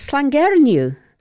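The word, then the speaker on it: Llangernyw: and I wasn't sure how to pronounce the name of the village. And so I asked the waitress, and my husband and I had a bit of a discussion on how to pronounce it, or my dad and my dad, because we had my dad with us. And, I got it right. It was Slangernyw.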